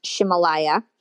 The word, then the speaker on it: Ximalaya: So I was mentioning an app called Shimalaya, and that's spelled X-I-M-A-L-A-Y-A, but it's pronounced Shimalaya.